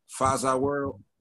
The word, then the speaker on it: Faza World: Faza World.